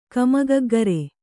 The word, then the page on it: ಕಮಗಗ್ಗರೆ: Kannada